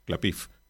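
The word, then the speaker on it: Klapisch: Cédric Caplich, o Clapiff, como sería una pronunciación más acercada a la denominación original de su nombre,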